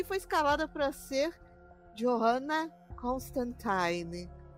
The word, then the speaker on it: Constantine: Era Constantine pra rimar com Valentine, por causa dos flertes dele com o sobrenatural.